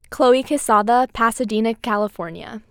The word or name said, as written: Chloe Quezada